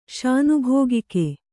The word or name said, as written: ಶಾನಭೋಗಿಕೆ